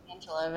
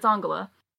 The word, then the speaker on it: Angela: They call it Angela, right? – Angela, which, you know, if you've only ever seen it written down and you're an American, okay, but it's Angela.